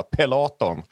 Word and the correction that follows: Platon: Uttalade, sa jag så? – Du sa Pelaton. – Platon, mattan. Ja.